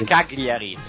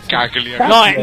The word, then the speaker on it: Cagliari: Al volo, invece, per quanto riguarda il rigore di Torino-Cagliari, non è Cagliari, è Cagliari. – Cagliari. – No, è Cagliari. – Cagliari. – È come gioielliere.